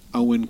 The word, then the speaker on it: Eoin: author Eoin Colfer. Uh, it's not spelt Owen, but that's how I found out how you say it because I didn't want to butcher his name.